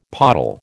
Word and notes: pottle: American English

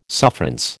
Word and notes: sufferance: American English

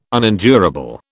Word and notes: unendurable: British English

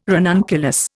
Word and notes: ranunculus: Indian

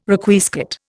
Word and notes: Requiescat: Indian

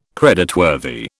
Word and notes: creditworthy: British English

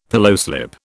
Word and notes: pillowslip: British English

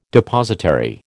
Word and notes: Depositary: American English

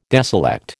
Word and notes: deselect: American English

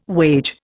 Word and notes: wage: Indian

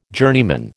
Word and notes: journeyman: American English

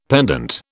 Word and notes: pendant: British English

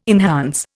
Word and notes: enhance: Indian

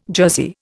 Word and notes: jersey: Indian

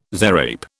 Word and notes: zarape: British English

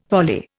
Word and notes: volley: Indian

